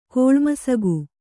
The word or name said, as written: ಕೋಳ್ಮಸಗು